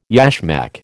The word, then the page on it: yashmak: American English